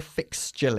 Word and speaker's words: fixture: Have you seen the fixture list? Dije sí, centrándote siempre en la pronunciación de ch, la T-U-R-E.